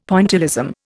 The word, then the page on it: Pointillism: Indian